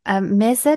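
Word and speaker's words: Meze: gibt es zum Beispiel echt griechisch, Griechenland vegetarisch, Athen das Kochbuch, griechische Mese, das spricht man auch weich aus, ne? – Genau.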